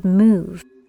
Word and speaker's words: eMOOV: I bring to you today a supportive tool called MOVE. It's spelled with a lowercase e, followed by all capital M-O-O-V. Move. You might notice the E is silent, and soon you will learn why.